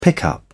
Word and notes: pickup: British English

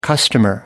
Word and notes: customer: American English

